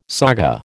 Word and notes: saga: American English

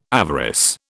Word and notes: avarice: British English

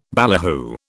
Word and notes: ballyhoo: British English

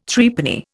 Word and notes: Threepenny: Indian

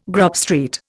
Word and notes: Grub Street: Indian

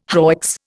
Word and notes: hoicks: Indian

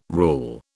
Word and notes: rule: British English

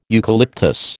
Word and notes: eucalyptus: American English

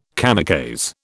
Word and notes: kamikaze: British English

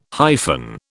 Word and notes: hyphen: British English

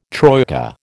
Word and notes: troika: American English

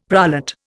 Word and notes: prolate: Indian

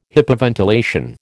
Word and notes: hypoventilation: American English

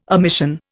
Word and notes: omission: Indian

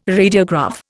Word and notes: radiograph: Indian

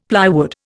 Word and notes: plywood: Indian

